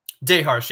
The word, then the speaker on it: Daharsh: Daharsh, yep.